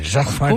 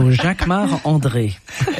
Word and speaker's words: Jacquemart-André: You say it like André. – A Frenchman. Jacquemart-André. – Of course, because I'm French. So Jacquemart-André.